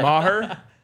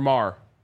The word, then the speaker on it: Maher: Maher or Mar? – It's Mar. – That's what I always thought.